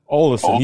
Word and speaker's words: Olesen: O-L-E-E-S. How you pronounce that? – Olesen, Thorbjørn Olesen. – Yes. – Yeah. So we'll call him Olsen.